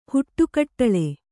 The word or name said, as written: ಹುಟ್ಟುಕಟ್ಟಳೆ